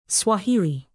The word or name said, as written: swahili